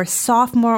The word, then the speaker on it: sophomore: So again, this pronunciation, it's spelled SOPHOMORE, but the PH is like in phone. It's a F sound. – Yeah. – And it almost sounds like sophomore. – You actually don't say the second O.